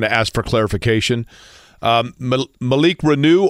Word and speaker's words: Reneau: Yeah, it's Malik Renew, and I'll tell you one other.